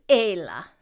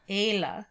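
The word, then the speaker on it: Ála: I know the pronunciation is Ayla. – Ayla. – Just like A-Y-L-A. And that's because the Fada makes it A.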